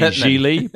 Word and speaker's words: Gigli: It's Ben Affleck plays a hitman named Larry Gigli, pronounced like really, which he has to address I mean, how can you name a movie? – Just change the name.